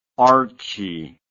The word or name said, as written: archie